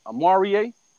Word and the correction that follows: Oruwariye: Oh, yeah. Oruwariye. – Oruwariye.